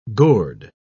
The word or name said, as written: gourd